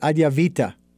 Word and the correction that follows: Advaita: Ayurveda. – Yes. Well, no, not, yes, Ayurveda, but also of the non-dualism. – Advaita. Yes, you got it.